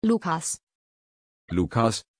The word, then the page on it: lucas: German